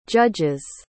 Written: judges

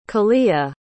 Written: kahlea

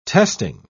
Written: testing

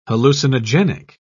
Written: hallucinogenic

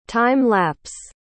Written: time-lapse

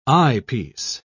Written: eyepiece

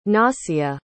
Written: nasia

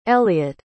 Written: elliette

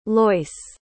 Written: loyce

